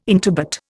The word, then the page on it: intubate: Indian